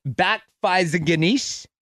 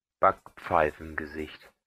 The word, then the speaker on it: Backpfeifengesicht: Backpfeifengesicht.